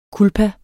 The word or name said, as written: culpa